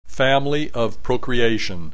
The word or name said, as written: family of procreation